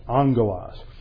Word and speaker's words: angelos: Well, a little rule of Greek pronunciation is when you have a double G, it's pronounced like it's an NG. So it is pronounced angelos.